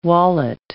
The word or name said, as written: wallet